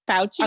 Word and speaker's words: Fauci: Is that how you say his name? – Fauci. – Okay, Dr. Fauci. Fauci. Mm-hmm. Okay. – It's Italian. You know. Fauci. – Okay. Dr. Fauci.